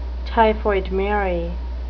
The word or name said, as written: typhoid mary